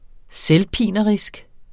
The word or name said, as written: selvpinerisk